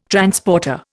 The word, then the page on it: Transporter: Indian